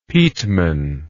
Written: peteman

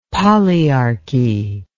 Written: polyarchy

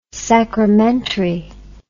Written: sacramentary

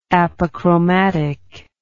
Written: apochromatism

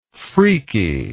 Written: freakery

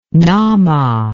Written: Nama

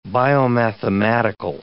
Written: biomathematical

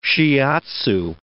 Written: shiatsu

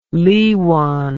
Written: liwan

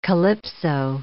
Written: calypso

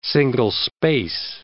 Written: single-space

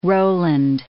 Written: Roland

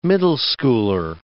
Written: middle schooler

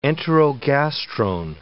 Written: enterogastrone

